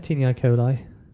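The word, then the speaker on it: taenia coli: The first and most obvious of which is the, I'm always going to pronounce this wrong, Tinii coli, is that right? – Yeah, Tinii coli.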